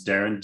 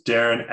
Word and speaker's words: Derryn: So it's Darren, D-E-R-R-Y-N. You can see how my name is spelled there. So it's Darren XL Medium on TikTok.